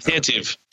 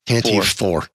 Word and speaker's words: Tantive: But he corrected me about the tentative. God, I can't say it right. – The tantive. – Tantive four. Yes. – Yeah, there's only two T's.